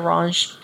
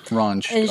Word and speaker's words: Ranj: They call him Dr. Ranj. – Ranj, R-A-N-J.